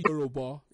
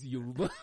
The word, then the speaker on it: Yoruba: He asked one of them to pronounce Yoruba, and he said, "It's not Yoruba, it's Yoruba."